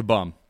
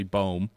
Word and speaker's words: Bohm: I don't think I can get to Bum. The reason I'm mispronouncing her name, it's actually Bohm.